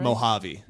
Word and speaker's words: Mojave: New on DVD this week, Breakpoint, Mojave. Am I saying that right? – Mojave. – Mojave.